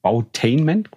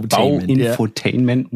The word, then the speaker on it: Bauinfotainment: Folge veröffentlicht und sie selbst nennt es Bautainment quasi. – Ist auch geil. – Oder Bauinfotainment, muss man es eigentlich richtig aussprechen.